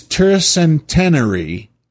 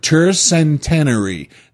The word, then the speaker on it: tercentenary: Related to a 300th anniversary, tricentennial, his tercentenary, let me see if I'm getting this right, his tercentenary year, that's the word, tercentenary, that's T-E-R-C-E-N-T-E-N-A-R-Y, tercentenary. I don't think I said it right in the first part, but we've got it corrected now, haven't we?